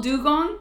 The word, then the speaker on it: dugong: Oh, it's called dugong, but they spell it D-E-W. – Oh. – But okay. It's literally just that animal. Look at it! Look at it! Look at it! It's the same animal. They just spelled it wrong. – It's pretty similar, and it's pronounced the exact same.